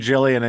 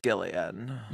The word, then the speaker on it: Gillian: I was saying Jillian and Joe's like, it's Gillian.